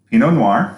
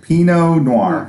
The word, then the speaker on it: Pinot Noir: We're going to talk about Pinot Noir.